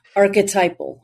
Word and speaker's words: archetypal: Uh, mentioned that she has done tarot readings, past life regressions, and archetypical okay, I sometimes I mispronounce this archetypical embodiment. Archetypal embodiment. Archetypal. Okay, sorry about mm-hmm.